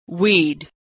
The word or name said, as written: we'd